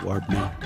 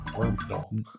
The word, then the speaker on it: Warb Null: And you have to say it like that so it sounds like you're doing the song from the end of Return of the Jedi. Warb Null. – Warb Null.